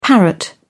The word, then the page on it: parrot: British English